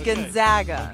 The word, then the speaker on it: Gonzaga: It's Gonzaga. – Okay, Gonzaga. – You better believe I did ask how you pronounce his school's name because I hear it pronounced the other way so often, especially during sporting events.